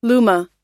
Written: Luma

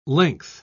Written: length

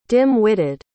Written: dim-witted

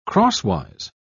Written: crosswise

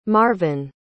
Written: marvin

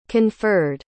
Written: conferred

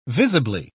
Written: visibly

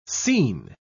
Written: seen

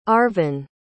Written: Arvin

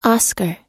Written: oscar

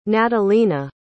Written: natalina

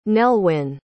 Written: nellwyn

